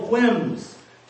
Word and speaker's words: whims: Personal whims. Gotta pronounce the H. Whims.